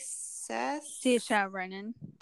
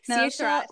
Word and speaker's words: Saoirse Ronan: Saoirse Ronan. – Thank you. Do that again. – Saoirse. – Thank you. I was talking over you before. – Saoirse Ronan.